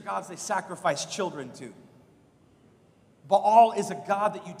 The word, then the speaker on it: Baal: And the people of Israel did what was evil in the sight of the Lord and served the Baals. By the way, it's actually pronounced Baal, and those are false gods.